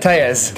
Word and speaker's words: Telles: It's not Tells, and it's not Tellies. – It's pronounced Tejas. – Tejas. – Who would have known that? I mean, where in the dictionary does it show that? It's just T-E-L-L-E-S.